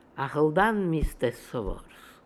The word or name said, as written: Akhaldanmistessovors